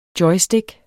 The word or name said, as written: JOYSTICKENE